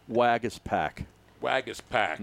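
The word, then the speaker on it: Waguespack: Wagis Pack. Not Wolf Pack. – No. – And it's pronounced Wag, Iss, I-S-S, Pack. Wagis Pack.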